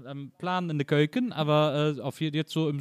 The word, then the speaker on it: Keuken: Keuken, in de Keuken.